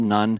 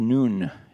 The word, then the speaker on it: Nun: I have always said the son of Nun. I found out this week that it actually should be pronounced Noon. It's more of a Hebrew pronunciation, and also that doesn't offend our Catholic friends who say, hey, a nun cannot have a son.